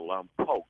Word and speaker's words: Lompoc: Is it Lompoc? – Yeah. It's actually, the locals call it here a Lompoc. – Lompoc. All right. – I think it derives from an American Indian name. – All right. Lompoc, California.